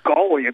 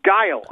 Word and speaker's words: Guile: Everyone used to call him Gully at the arcade we went to. Gully, including you. You probably still call him that. But the name is Guile. I learned the correct pronunciation of that name, you know, after a short amount of time. So I just called him what his name actually was, Guile.